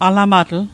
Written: alamatl

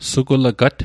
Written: sugulegat

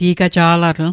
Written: gi'gaja'latl

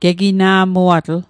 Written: gegina'muatl

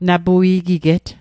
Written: napui'giget

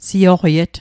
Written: seioqiet

